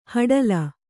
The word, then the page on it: ಹಡಲ: haḍala